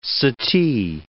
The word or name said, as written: sati